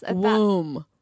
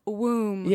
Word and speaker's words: womb: Womb. – Womb. – Yeah. – Well, and it has a B. – There's a B that's is the B even there? – No, it's like thumb.